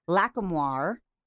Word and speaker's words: Lacamoire: And we have executive music producer Alex Lacamoire. Is that how I say it? – Yeah, I say it with the French pronunciation exactly like that.